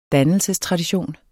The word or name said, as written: dannelsestradition